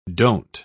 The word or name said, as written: don't